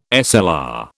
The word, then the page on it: SLR: British English